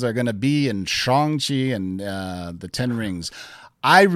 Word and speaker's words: Shang-Chi: Go follow Simu Liu's Twitter and Instagram, and he says what the correct pronunciation is, Shang-Chi. And when Kevin Feige first announced it at San Diego Comic-Con, he even came out and said it's Shang-Chi. So S-H-O-N-G.